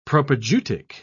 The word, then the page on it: propaedeutic: British English